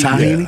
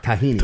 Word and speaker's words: tahini: Like, if you lose your voice or if you have a sore throat, little tahini, you got to get used to the sesame seed. – Tahini? – Yeah. Tahini. – Tahini. – Tahini. – They've been accent on the ta. – It's just tahini.